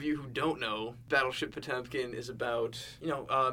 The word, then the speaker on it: Potemkin: This was recently we all got to have a screening of the classic 1925 Soviet film Battleship Potemkin. – Correct. – I'm saying that right? – Yes, you are.